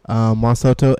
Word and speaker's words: Juan Soto: Juan Soto.